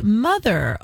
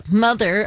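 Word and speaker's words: M.O.T.H.: Kicking off tonight's visit to Planet Nunu on 91X with a new band called Mother. Although, get this, it's spelled M period O period T period H period. You get the idea. Mother, a band that features Jamie Hintz of The Kills.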